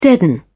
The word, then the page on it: deaden: British English